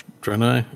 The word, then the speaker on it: Draenei: Draenei. Yeah, I'm saying that right.